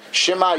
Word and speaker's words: Shema: So the first word of Kriya Shema then is obviously a Shvanach, and it's read Shema Yisrael, not Shema, as most of us do.